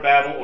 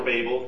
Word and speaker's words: Babel: So that in the Tower of Babel, or Babel, you can pronounce it either way, God dispersed the people, because they were getting full of themselves and trying to build a tower.